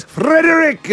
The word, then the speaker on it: Frederick: Yes, Frederick is how you say his name. – So Frederick. – You've got to roll the R.